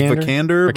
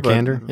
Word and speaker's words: Vikander: It was Vikander. – Vikander.